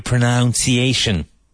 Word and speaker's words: pronunciation: Now, so many English teachers still say, you have to improve your pronunciation. They have to improve their pronunciation. So, to pronounce, but pronunciation.